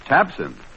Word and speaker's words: Tabcin: If you have either of these unpleasant ailments, remember, your misery can be relieved quickly and effectively with Tabsin. That's spelled T-A-B-C-I-N, Tabsin. The bright red tablets in the bright red package at all drugstores.